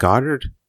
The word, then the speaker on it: Goddard: Is it Goddard? – Yep.